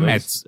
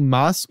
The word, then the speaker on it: Mads: É, é Mads, é. – é Mads. Uh, eu vi que tanto faz, tanto Mas quanto Mads. Ah, né? Tá. os dois, as duas pronúncias estão corretas.